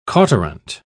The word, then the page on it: cauterant: British English